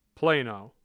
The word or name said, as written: Plano